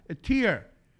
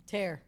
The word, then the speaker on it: tear: Tear. – Tear. – He teared it apart. – Y se escribe igual.